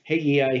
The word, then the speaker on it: Haggai: You'll see it pronounced Haggai here, and you'll see it also as Hega, H-E-G-A, without the I.